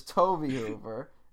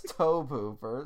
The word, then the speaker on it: Tobe Hooper: I failed to do the one thing I promised myself to do, because we got a lot of comments saying we pronounced Toby Hooper's name wrong. – Yes, we did. – And I was insistent to you that it was Toby Hooper. And you were like, no, it's Tobe Hooper.